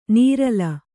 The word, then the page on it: ನೀರಲ: nīrala